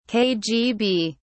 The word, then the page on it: kgb: British English